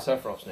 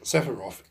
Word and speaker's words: Sephiroth: they don't pronounce it Sephiroth in the same way. It's like, Sephiroth. – Yeah. – Like, they don't put the H on the end.